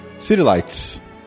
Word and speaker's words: City Lights: City Lights.